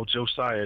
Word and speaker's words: Josiah: Prophet like prophet, and then, you know, Josiah, just like the dude in the Bible.